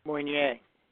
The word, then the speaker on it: Moynier: Moynier.